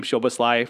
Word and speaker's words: Szoboszlai: I actually found out how to say his name, Szoboszlai, for RB Salzburg.